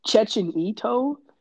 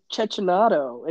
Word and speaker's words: Cecchinato: Chechenito? – Is it like Chechenito? – It's Chechenato. It's an Chechenato. A. Chet C E C C H I N A T O. Chechenato.